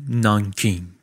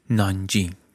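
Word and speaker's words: نانجینگ (南京): تو این وضع آمدن جلو، بیشتر پیشروی کردن، رسیدن به دومین هدف بزرگ در مسیرشون، پایتخت چین ناسیونالیست، شهر نانکینگ یا با تلفظ محلی نانجینگ.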